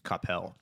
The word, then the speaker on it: Coppell: I ate at a place for a wedding reception once, and outside of Coppell, Texas, mm-hmm. another place. The brisket at both were comparable. They were amazing. So, but you said it wrong, by the way. It's Coppell.